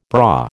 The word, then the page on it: braw: American English